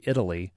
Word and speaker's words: Italy: What does I-T-A-L-Y sound like if I'm in Dallas? – Well, it's Italy, so it's kind of a two syllable pronunciation. What you might say Italy, but they pronounce it Italy.